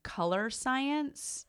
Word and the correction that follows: Colorescience: It's Color E Science, all one word.